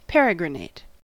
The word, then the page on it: peregrinate: American English